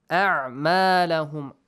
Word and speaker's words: أَعْمَالَهُمْ: أَعْمَالَهُمْ make sure we have Hamza and Ayn, both of them. Do not mispronounce them.